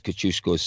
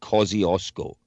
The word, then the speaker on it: Kosciuszko: Now, the very strange thing is, in Australia's biggest mountain is spelt exactly the same way as Kosciuszko's surname, and they pronounce it Kosciuszko. How'd you get Kosciuszko and Kosciuszko from the same grouping of letters?